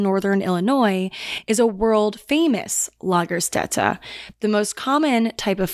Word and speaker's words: Lagerstätte: and it is Lagerstätte, and it's spelled, I could have totally butchered that pronunciation. I Googled the pronunciation, that's what it said, but the way it's spelled is L-A-G-E-R-S-T-A with two dots over it, T-T-E, Lagerstätte.